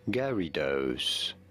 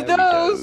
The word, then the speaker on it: Gyarados: is as Garydos. Somewhat like Gary and Dose. – Gary, Gary Dose.